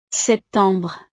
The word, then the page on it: septembre: frances